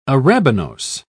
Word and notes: arabinose: British English

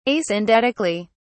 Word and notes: asyndetically: British English

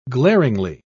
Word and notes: glaringly: British English

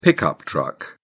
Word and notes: pickup truck: British English

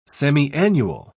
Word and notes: semiannual: British English

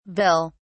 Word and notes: vill: British English

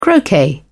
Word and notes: croquet: British English

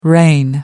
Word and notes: reign: American English

